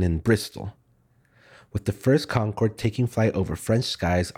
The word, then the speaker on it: Concorde: And that's exactly why the plane was named what it is, Concorde. It's pronounced the same in French and English, and it means agreement, harmony, or union.